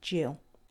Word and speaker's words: gill: The word is actually written down as G I double L, but it's pronounced Jill.